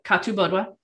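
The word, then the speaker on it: Cathubodua: And then we have Katu Bodwa. The way Katu Bodwa is spelled is Kathu Bodwa, but the H in the Gaulish is silent.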